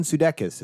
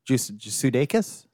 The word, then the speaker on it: Sudeikis: And Jason Sudeikis has really proven himself as I've not heard it pronounced that way. – Sudeikis? – Sudeikis.